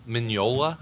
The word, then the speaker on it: Mignola: Is that how you say his name? – Mignola? – Yeah. Yeah, the G is silent.